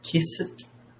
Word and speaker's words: kissed: Well, I kissed. – Make sure, one sound. So one syllable, kissed.